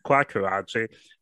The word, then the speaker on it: Kwaku Adjei: Kwaku Adjei. It's four syllables.